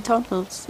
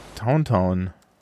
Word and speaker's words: Tauntaun: Die schreibst du halt Tauntaun. T-A-U-N. – Tauntaun. Ah ja, okay. Ja. Es gibt... pronounced Tauntaun. Okay. Da steht die Phonetik dahinter. – Okay. – Ja. Ich werde die Artikel mal gleich da lassen, ne? Für die Shownotes. So, also Tontons ist natürlich deutsche Aussprache, ne? Tontons ist es.